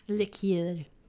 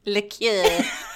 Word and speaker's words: liqueur: How do you say that? – Liqueur. – Liqueur.